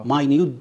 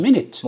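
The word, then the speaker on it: minute: فتجمله مينت مثلا، مينت كلنا بننطقها مينت، لكن لما تيجي تقول معلومات دقيقة. مينيوت ديتيلز. مينيوت مش منت.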